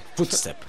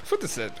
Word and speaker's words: Footstep: ¿Cómo, se pronuncia esto? – Footstep. – Footstep.